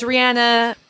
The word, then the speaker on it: Rihanna: It's Rihanna, but there's not a lot of her in this profile.